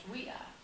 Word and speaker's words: via: We it's spelled V-I-A, but it would have been pronounced Wea. And it means like road or way, V, V-I-A.